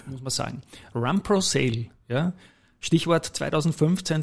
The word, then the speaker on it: runprosale: Stimmt Run Pro Sale überhaupt und ja, was ist die Idee? Es stimmt perfekt, äh, perfekt ausgesprochen. Aber es stimmt natürlich auch, dass da speziell zu Beginn, äh, wie ich gestartet habe, 2015, zu gewissen Abwandlungen gekommen ist. Mit äh, das Beste war äh, Rundprosale.